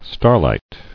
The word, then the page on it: Starlight: star·light